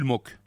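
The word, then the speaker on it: Moch: Jules Mock. Ça s'écrit Moche, mais ça se prononce Mock.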